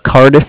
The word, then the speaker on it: Cardiff: Yep, Kyle, and Cardiff is spelled C-A-R, D as in David, I-F-F, like Frank.